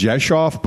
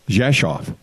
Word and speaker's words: Rzeszów: And the towns, and remember I said the towns, the town I'm going to, it's pronounced Jeshoff, but it starts with an R. And there's no V in it. And there's no F. So you look out, it looks like Rezikov. When people go in, it looks like Rezikov. Rezikow, but people pronounce it, it's in Polish, it's pronounced Rzeszów. The hometown Abby is from, it starts with an S, a D, and a Z.